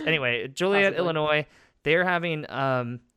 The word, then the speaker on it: Joliet: Is that how you say it? – I think so. Joliet. Yeah. All right. It's like Juliet with an O.